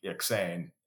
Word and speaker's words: Eksayn: You spell it E-K-S-A-Y-N. So E-K-S as in Sam, A-Y-N as in Nancy. So Iksane.